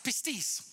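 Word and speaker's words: pistis (πίστις): The word faith in the New Testament is pistis. That's how it's pronounced, pistis.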